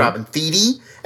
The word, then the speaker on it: Thede: Just learned how that's how you pronounce her name. Mm-hmm. Robin Thede.